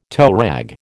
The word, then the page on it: toerag: American English